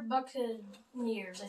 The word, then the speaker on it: Buccaneers: The Tampa-neer Buccabays. – No, the Tampa... The Tampa... – Tampa-neer Buccabays. – No, Tampa-neer Buccaneers, I think.